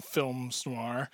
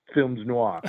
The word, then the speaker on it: films noir: But you convincingly make a case that there are actual cocktails in films noir. So tell me about that. – Interesting. And I'm giving you bonus points for that correct pronunciation of films noir.